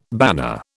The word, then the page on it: banner: British English